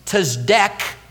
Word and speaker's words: Tzedek: Tzedek is how it would be pronounced, and it means righteousness.